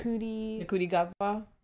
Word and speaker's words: Ncuti Gatwa: Ncuti Gatwa.